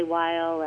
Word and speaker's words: Weill: It's Kelly Weil, and I tweet about conspiracy theories, current events, so give me a follow if you'd like.